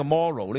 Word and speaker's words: Moreau: 咁但係原來咧，佢喺 1896 年咧，佢寫咗本小説叫做《莫洛博士島》啊，《 The Island of Dr. Morrow》。咁 Morrow 咧就係 M-O-R-E-A-U 嘅。咁但係好有趣㗎，啲深水清嘅人都話咧，其實佢呢個 Morrow 呢個字咧，即係個村就。好似冇乜特別啦，係一個姓氏嚟嘅啫。但係你個讀音咧就係同呢一個道德差唔多音嘅，moral 啊嘛，係嘛？就係、 M-O-R-E-A-U 就同 M-O-R-A-L moral。